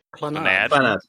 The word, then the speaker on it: Clannad: Clannad. – People say Clannad, and that doesn't make any damn sense. So I've always said Clan in my head. – What language is that word? Clannad? – It's supposed Welsh?